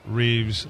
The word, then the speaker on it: Rives: David Reeves, and I want to give your website. It's just David Reeves, and you got to understand it's spelled R-I-V-E-S, Reeves that way.